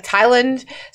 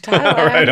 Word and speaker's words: Tyland: I've never heard you say that, I don't think, but like you say it like Tyland, so it just sounds like Thailand to me. I've never thought of it. Tyland Lannister.